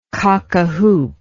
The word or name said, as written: cockahoop